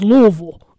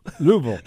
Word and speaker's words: Louisville: When I first went to Kentucky and I said something about you all, I said, well, first of all, you need to learn how to say it. It's y'all, and it's Louisville. – Louisville.